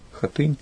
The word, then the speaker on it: Хатынь: То есть это самая настоящая смоленская хатынь. Ну или как у нас, хатынь, с таким ударением принято называть.